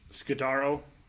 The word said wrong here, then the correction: Skura: You can say Yannick Ngakoue, but you can't say Skura.